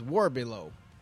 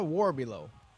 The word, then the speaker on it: Warbelow: Now, her name is spelled, if you took the word war and below and put those together, her name is Warbelow. Sarah Warbelow.